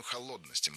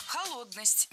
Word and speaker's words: холодность: Холодность. Неверно. Правильно. Холодность. Ударение в этом слове такое же, что и в исходном существительном. Холод. Холодность. Легко запомнить.